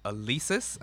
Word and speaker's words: Alesis: It's called Alesis, I think is the pronunciation. – Okay, yeah, I've heard of Alesis for sure.